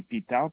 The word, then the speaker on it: Pitard: Mon nom, c'est Charles Pitard Jean-Luc. – Pitard? – D'accord. Oui, Charles Pitard Jean-Luc. – Ça s'écrit comment, s'il vous plaît? – Le nom, comme Charles avec un S, et Pitard, P-I-T-A-R-D.